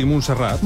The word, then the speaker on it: Montserrat: ¿Lo he dicho bien? ¿Montserrat? – Sí, Montserrat. – Montserrat. Muy no bien. He dicho bien. Montserrat. – Sí, sí. Montserrat. Y hablando del Papa, precisamente se ha juntado con Bad Bunny.